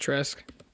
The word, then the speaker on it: Trask: The Bucs aren't trading up for Kyle Trash. It's pronounced Trask.